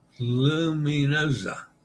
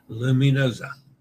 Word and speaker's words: Luminosa: So, Luminosa. Remember that the S between vowels is pronounced Z as in pizza or lasagna. Luminosa. Okay. I put too much S in it, I see.